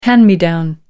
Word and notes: hand me down: American English

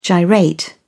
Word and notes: gyrate: British English

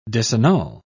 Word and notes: disannul: British English

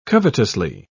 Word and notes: covetously: British English

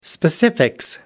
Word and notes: specifics: American English

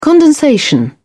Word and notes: condensation: British English

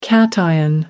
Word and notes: cation: American English

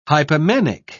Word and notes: hypomanic: British English

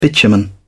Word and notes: bitumen: British English

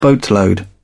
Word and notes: boatload: British English

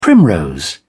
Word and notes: primrose: British English

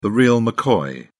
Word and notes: real mccoy: British English

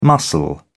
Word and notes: mussel: British English

